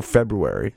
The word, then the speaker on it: February: February or February? – February. I say February. It's my birth month. I leave that other R out of there.